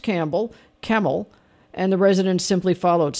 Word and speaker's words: Campbell: Well, Campbell is pretty interesting because, of course, it is Campbell, but it's pronounced Camel.